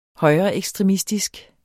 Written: højreekstremistisk

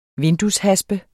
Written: vindueshaspe

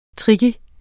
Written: tricky

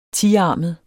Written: tiarmet

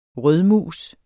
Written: rødmus

